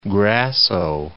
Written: Grasso

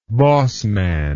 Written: bossman

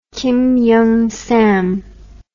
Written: kim young sam

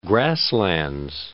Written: Grasslands National Park

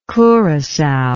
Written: curacao

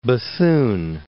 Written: Bethune

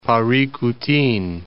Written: Paricutin

